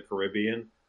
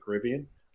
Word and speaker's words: Caribbean: So that's probably what inspired the original Pirates of the Caribbean. Uh, but yeah, how it's pronounced is it's Caribbean, Sorry.